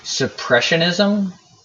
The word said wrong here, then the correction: supersessionism: supersessionism. That's it.